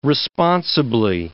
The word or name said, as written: responsibly